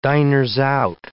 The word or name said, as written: diners-out